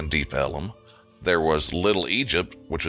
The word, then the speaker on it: Deep Ellum: because of Texas pronunciation, it became known as Deep Ellum.